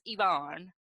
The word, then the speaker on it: Ivan: So we witnessed 2 more couples, Alex and Yvonne, even though his name is spelled Ivan, I-V-A-N, but it's Yvonne, and Connie and Jonathan.